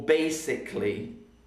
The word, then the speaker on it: basically: Now basically has a silent letter because I don't say basically, I say basically, not basically, basically. So the A is silent because we don't say it.